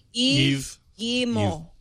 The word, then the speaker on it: Yves Guillemot: Yves Guillemot.